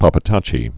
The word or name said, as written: pappataci fever